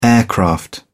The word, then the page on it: aircraft: British English